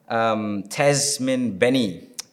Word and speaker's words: Tasmyn Benny: She's actually the first female boxer that we have on, Tasmyn Benny.